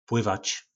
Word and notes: plyvac: po nasimu

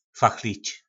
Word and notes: fachlic: po našimu

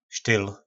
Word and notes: styl: po nasimu

